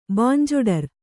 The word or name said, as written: ಬಾಂಜೊಡರ್